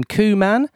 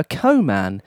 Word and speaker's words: Koeman: People often pronounce his surname Koeman. However, he does himself prefer Koeman. This is because throughout his career, he was often given the nickname Koeman the Pooman.